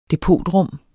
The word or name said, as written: depotrum